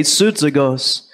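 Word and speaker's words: Soudzagos (σύζυγος): Well, the word yoke fellow that is transliterated from the very Greek is pronounced this way, Soudzagos.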